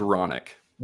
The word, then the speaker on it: Hronek: General Hronik, am I saying that right? H-R-O-N-E-K, Hronik? – I think it's Ronick. – Ronick. It's ironic.